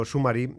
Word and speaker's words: SMMRY: Sí, mira, tenemos por ejemplo Summary, se escribe SMMRY, que resume textos largos incluyendo PDFs.